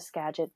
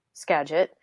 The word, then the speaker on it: Skagit: for value-added agriculture here in the Skagit Valley.